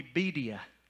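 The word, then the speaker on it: birria: And it's going to be bedia. You like that, Jerry? You like how I did that to you? You're going to have bedia tacos. Did I say it right? Yeah. Okay, I said it good, right?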